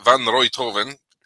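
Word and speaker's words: Van Rijthoven: Van Roythoven così ci hanno detto che si pronuncia,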